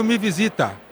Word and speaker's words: Me Visita: Aqui eles pronunciam Me Visita, porque já é parecido, visita, visita. Então nos ajuda muito em países de língua latina.